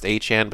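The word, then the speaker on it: Etienne: he had come out and said his last name is pronounced Achane, but that's going to be really annoying to differentiate with the Achane in Miami.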